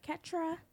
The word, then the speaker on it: Ketrya: My name is Fit Like Ketra.